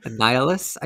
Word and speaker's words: Nihilus: Nihilus, I think is how his name is pronounced. – Mm-hmm. Yep, yep, yep.